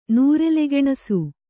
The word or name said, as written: ನೂರೆಲೆಗೆಣಸು